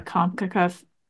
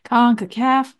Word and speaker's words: CONCACAF: CONCACAF, I just said it wrong, CONCACAF Champions Cup matches in club history, which I thought was pretty interesting, little tidbit.